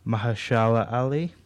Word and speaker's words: Mahershala Ali: I didn't recognise him first, and also Mahershala Ali.